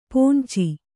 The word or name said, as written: ಪೋಂಚಿ